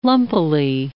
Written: lumpily

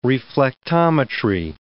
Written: reflectometry

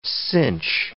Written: cinch

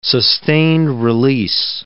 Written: sustained-release

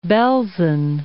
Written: Belsen

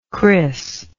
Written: chris

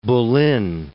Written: Boleyn

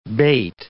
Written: bate

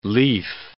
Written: leaf

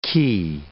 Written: ki

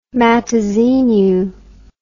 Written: Matozinhos

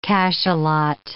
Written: cachalot

